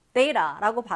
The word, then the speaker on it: data: data라고 발음이 되기도 하고 data라고 발음이 되기도 합니다.